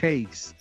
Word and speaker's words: Hayes: Carmelo Hayes.